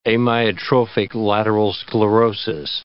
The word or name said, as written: amyotrophic lateral sclerosis